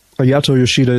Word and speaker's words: Ayato Yoshida: We saw at least, you know, kind of one young lion kind of making his ascension to the main show yesterday with, I know I'm going to butcher his name, was it Ayato Yoshida? – Ayato Yoshida is correct, but he's not a young lion.